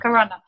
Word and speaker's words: Corunna: Corunna.